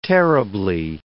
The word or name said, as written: terribly